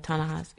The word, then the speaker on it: Ta-Nehisi: There was a big, and I'm going to screw this up, Ta-Nehisi Coates? – Uh-huh. – Is that right? – Right. – Is that the right pronunciation? – Yeah, Ta-Nehisi, yeah.